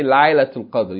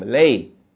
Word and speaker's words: Laylatul Qadr: Like in Laylatul Qadr. You don't say Laylatul Qadr, you say Laylatul Qadr. That's Lam, Fatta, Ya with sukun, Lam. Lay. Huh?